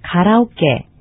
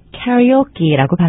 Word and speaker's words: karaoke: 지금 들으신 문장을 통해서 들으셨겠지만 가라오케. 미국 사람들은 karaoke라고 발음합니다.